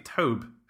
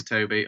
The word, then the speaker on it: Tobe: Are you pronouncing it Tobe Hooper or Toby? I would have thought it's just Toby, T-O-B-E. Yeah, yes, probably is Toby.